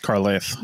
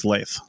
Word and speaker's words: Carleith: I imagine it's Carlisle. – Carlisle, maybe. Yeah. C-A-R-L-E-I-T-H.